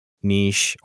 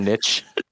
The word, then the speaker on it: niche: Apparently, niche is the historical pronunciation that is more popular in the U.S. Niche came about likely under influence from French pronunciation. It's kind of whatever. And it's more popular in the UK.